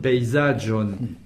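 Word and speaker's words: pejzaĝon: Paysagion, yes. Alors, paysagion, ça s'écrit P-E-J. Donc, pay, c'est une seule syllabe.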